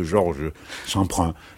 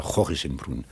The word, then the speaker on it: Jorge Semprún: Mais vous voyez comme c'est pauvre, ce que je vous raconte là, par rapport à Georges, parce que, à Jorge, moi j'aime bien prononcer le mot Jorge Semprún, je trouve que ça sonne bien, Jorge Semprún, ça sonne plus que Georges Semprin, ça sonne bien, mais je préfère Jorge Semprún.